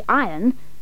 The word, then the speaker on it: iron: Please remember to say iron so that it'll rhyme with lion.